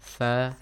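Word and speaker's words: Tha (ث): That's tha, right? – Yes. – Tha. – Tha. How do you pronounce it? – We use our tongue between our teeth. – Yeah. Between your teeth, front teeth, yeah? – Yes.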